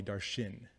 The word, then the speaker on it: Darshan: Like from kindergarten through like eighth grade, I actually hated my name because everyone called me Darshan.